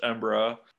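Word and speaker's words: Embarras: But anyways, the three tributaries of the Wabash I studied were the Vermilion, the Embera, it looks like embarrassed, but pronounced Embera, and the Little Wabash.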